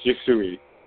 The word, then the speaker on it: Yusui: So pronunciation, is it Yushi or Yusui? – It's Yusui. – Yusui, right, cool.